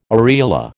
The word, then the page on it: areola: American English